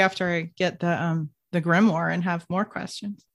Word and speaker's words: grimoire: It's like W-O-H-R is war. Grimoire. War, W-H-R is kind of the pronunciation.